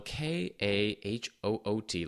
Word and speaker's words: Kahoot: And uh, Kahoot is spelled K A H O O T, like Kahoot, like a like an owl, like hooting.